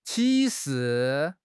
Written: 启示

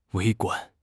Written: 微观

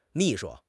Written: mi4 shu1

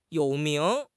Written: you3 ming2

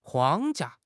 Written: 框架

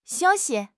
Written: xiu1 xi5

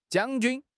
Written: 将军

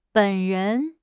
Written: 本人

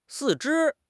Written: 四肢

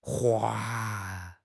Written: hua4

